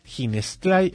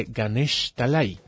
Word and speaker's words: Ganeshtalai: El niño decía Ginestlai en lugar de Ganeshtalai, sin saber leer ni escribir e incapaz de entender el idioma que se hablaba en Calcuta.